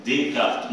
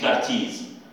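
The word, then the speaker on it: Descartes: ديكارت اسمه ديكارت مش كارتيز ما اسموش كارتيز ديكارت اسمه والاس تتكتب في اخر الحرف وما تتنطقش بالفرنسويه في آخر الجملة، في آخر الكلمة، مش ديكارتس، إس مكتوبة في اسمه لكن ما تتنطقش.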